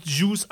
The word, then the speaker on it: Juice: Aber meinst du Juice ausgesprochen wie Saft oder wie Juden? – Nein, wie Saft.